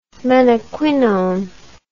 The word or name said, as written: menaquinone